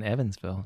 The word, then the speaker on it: Evansville: It's part of the perks of living in Evansville. – That's right. That's right. – Something that rhymes with heaven.